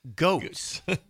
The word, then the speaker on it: Goetz: I called her Beth Goetz. – Beth Goetz. – Oh, it's spelled G-O-E-T-Z. I don't know Beth Goetz very well. I haven't heard her name out loud. It's Beth Goetz.